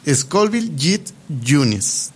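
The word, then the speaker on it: Scoville Heat Units: Yo no me iba a atrever a hablar de esto porque el nombre está en chino, bueno, no en chino, pero se pronuncia Scoville Jit Units. Son las unidades con las que se mide el picor de los chiles.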